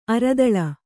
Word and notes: ಅರಿದಲ: aridala

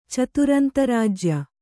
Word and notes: ಚತುರಂತರಾಜ್ಯ: kannada

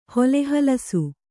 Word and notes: ಹೊಲೆಹಲಸು: hole halasu

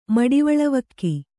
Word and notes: ಮಡಿವಳವಕ್ಕಿ: Kannada

